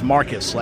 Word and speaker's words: Marcos: Even though it's spelled like Marcos, it's actually pronounced here in Texas as Marcus, like M-A-R-C-U-S, because that's the way Texans roll.